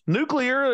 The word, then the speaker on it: nuclear: Nuclear. There's only one way you should pronounce it. Nuclear. Oh, there's no extra U in it.